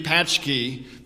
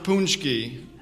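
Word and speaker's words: pączki: I know it looks like it should be patchki, but it's pronounced punchki. Okay?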